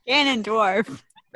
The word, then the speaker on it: Ganondorf: It's something like Gurnardief. Gurnardief. Am I saying that right? Gurnardief. – You're up. – Ganondorf. – That is correct.